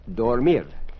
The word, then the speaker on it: dormir: Dormir. – Dormir. – D-O-R-M-I-R. Did you get that little trill? Dormir. Correcto? – Sí. – And to go to sleep, dormirse. – Dormirse. – D-O-R-M-I-R-S-E.